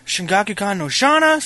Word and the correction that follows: Shakugan no Shana: Shakugan no Shana. – Whatever. How do you say it? – Shakugan no Shana.